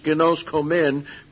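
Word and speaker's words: ginoskomen (γινώσκομεν): This word is ginosko, pronounced in this case ginoskomen, because we know.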